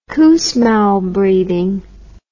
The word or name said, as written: Kussmaul breathing